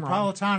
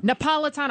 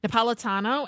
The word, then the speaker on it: Napolitano: I always say his name Napolitano. – Wrong. Napolitano. I want to say because he's from Naples, his family. Napolitano.